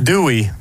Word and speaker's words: Dewey: Me wel af, hoe spreek je jouw voornaam uit? Hoe noemen ze jou daar in Amerika? – Dewey.